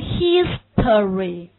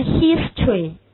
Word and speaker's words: history: 它这个词的读音就是，要不然你有的人会读成 history，而有的人也可以这个而不读，直接读成 history， history。